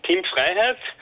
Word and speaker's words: Freiheit: Yes, it's Team Freiheit, but Team Freedom is also okay.